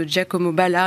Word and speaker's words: Giacomo Balla: Et enfin, euh, la galerie euh, italienne Antonacci Lapicirella, Fine Art, a vendu euh, les quatre saisons de Giacomo Balla, un artiste italien futuriste, pour, à un collectionneur privé pour un prix d'environ 1 million d'euros.